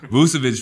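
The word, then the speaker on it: Vučević: and I had been a big Vujovic, I think that's how you say it. – Vucevic fan, like, ever since, like, you know, you just kind of think of, like, people that you compare to and play.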